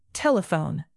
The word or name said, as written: telephone